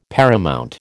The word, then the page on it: paramount: American English